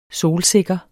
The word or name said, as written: solsikker